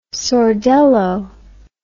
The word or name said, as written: sordello